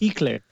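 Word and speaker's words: Helaire: And what's funny is I thought it was something Claire at the end. Like, I know it's pronounced Eclair, right? And I'm like, it's Hilaire. I'm like, yeah, oof, Hilaire. Jesus.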